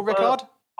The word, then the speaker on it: Ricard: You never say the D, Paul Ricard. – Paul Ricard. – I never say Paul Ricard. Do you say Paul Ricard? – I say Paul Ricard.